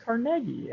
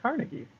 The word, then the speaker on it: Carnegie: Morgan, you know, Andrew Carnegie, which I recently learned is supposed to be pronounced Carnegie and not Carnegie, if you are interested. I guess it's a Scottish thing.